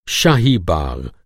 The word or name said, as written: बाग़